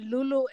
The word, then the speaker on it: Lolo: You know, because it's pronounced L-O-L-O. It's spelled L-O-L-O. It's pronounced Lolo. Lolo. Lolo. But if you wanted it to be the way we say it, which is Lulu and Lula, it'd be L-U-L-O. A and L-U-L-U.